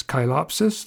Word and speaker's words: Chilopsis: Two ways to say that name, the genus Chilopsis, that would be the proper botanical pronunciation, or Chilopsis, the way I used to say it, that's reformed hillbilly.